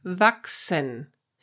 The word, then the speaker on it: wachsen: If CH is followed by S, then you pronounce it K. So the number six is sechs. It would be really hard to pronounce if it was H. And to grow is wachsen.